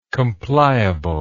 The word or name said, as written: compliable